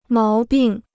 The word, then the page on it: 毛病: máo bìng